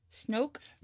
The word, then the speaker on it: Snoke: Rhymes with smoke. – Snoke? – There you go.